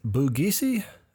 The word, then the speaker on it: Bugisu: And that name on that is Bugish? – I believe the pronunciation is Bugisi, B-U-G-I-S-U.